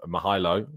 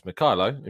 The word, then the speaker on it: Mykhaylo: So yesterday we completed the ultimate, as I described it, breakdown of Mykhaylo, not Mykhaylo. In one of the things we learned yesterday is the pronunciation of his name more correctly.